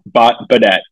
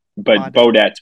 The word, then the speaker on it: Baudette: Pretty good. Baudette. It's actually just like B apostrophe D, but Baudette's pretty good. Yeah. Baudette, I guess, is the perfect pronunciation.